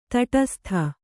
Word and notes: ತಟಸ್ಥ: taṭastha

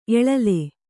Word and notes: ಏಳಿಲ: ēḷila

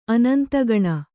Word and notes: ಅನಂತಗಣ: anantagaṇa

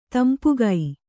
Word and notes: ತಂಪಿಗ: tampiga

